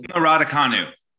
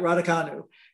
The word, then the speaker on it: Raducanu: Raducanu. – Right, Raducanu.